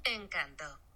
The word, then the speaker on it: Encanto: Encanto.